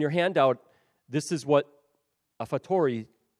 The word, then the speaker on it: a fortiori: And this is known as, and I'm probably going to not say this correct, so I'll just say that right off the bat, But I believe it said a fortiori. Yes, there we go. I've heard it a little smoother out there.